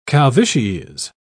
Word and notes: calvities: British English

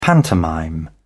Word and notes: pantomime: British English

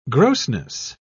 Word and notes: grossness: British English

